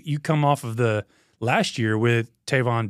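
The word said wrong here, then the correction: Trevon: I mean, did I say that right? Is it Trayvon or Tavon? – I think it's Trayvon. – Trayvon, yeah, so my bad.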